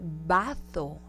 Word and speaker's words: bazo: Vamos a pronunciarlo en español de España, oiga. Así, de doblaje de película. El donde tomamos agua es el vaso. El vaso, y del que vamos a hablar ahorita es el bazo.